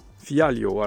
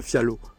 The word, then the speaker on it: Figueiredo: Fialio, à la, à la portugaise je prononce, je ne vais pas dire Fialo, Fialio.